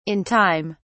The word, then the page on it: in time: British English